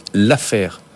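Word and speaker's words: Laffer: Jean-Paul nous écrit et dit « Une piste de recherche, et si la chute de la productivité était liée à un effet, l'affaire ?» Ou l'affaire, je ne sais pas comment on prononce.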